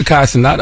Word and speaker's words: Kai Cenat: That's how you pronounce his name, Kai Cenat, right? – Sounds right. – Yeah, salute to Kai Cenat.